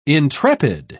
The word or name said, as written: intrepid